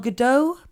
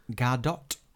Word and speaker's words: Gadot: did you say Gal Gadot? – Gadot. – It's actually Gadot. That's how... – Gal Gadot. – An actor recently who was interviewed who worked with her pronounced it. I can't remember who it was, but he says it's Gadot.